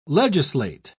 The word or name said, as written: legislate